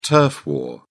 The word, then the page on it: turf war: British English